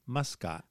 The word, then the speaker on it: Muscat: How do you say Muscat? – Muscat.